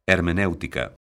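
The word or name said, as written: hermenéutico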